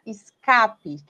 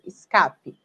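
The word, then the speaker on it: Ska-P: É uma música da banda Escape, que se escreve S-K-A, né, de ska, o ritmo, tracinho P, Escape.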